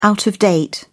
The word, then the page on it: out-of-date: British English